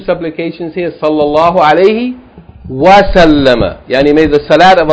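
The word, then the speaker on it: Sallama (سَلَّمَ): It's not salam. Salam is a noun. Sallama is a verb. Sallallahu alayhi wa sallama. It's a verb.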